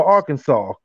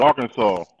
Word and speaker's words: Arkansas: And is it Arkansas or Arkansas? Because, you know, some people say it different. You'd be like, yeah, from Arkansas. The S is silent.